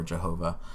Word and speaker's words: Jehovah: The Tetragrammaton, the YHWH, which they pronounce either Yahweh or Jehovah, means I am, but it is God.